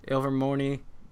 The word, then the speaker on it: Ilvermorny: I'm about to join my, what is it, Ilvermorny house? – Yes. – If that's how you pronounce it. – Mm-hmm. Ilvermorny, House of Witchcraft and Wizardry.